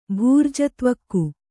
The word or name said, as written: ಭೂರ್ಜತ್ವಕ್ಕು